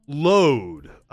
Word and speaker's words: Lod: Lod.